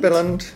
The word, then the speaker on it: Billund: If anyone's thinking about going to Billund, is that how you say it? Billund? – Billund. – Billund. – Yeah. Yes. Billund.